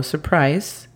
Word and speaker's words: surprise: Y después, sorpresa o surprise sería S-R-P-R-A-I-S. Nos estamos brincando la U porque no queremos hacer el sonido de la U ahí. Queremos decir surprise, surprise.